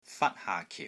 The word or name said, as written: FAT1 HAA5 KIU4